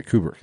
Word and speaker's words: Kubrick: Yeah, Kubrick.